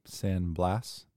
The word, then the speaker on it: San Blas: I think it's pronounced Cape San Blas, B-L-A-S, the ship sank.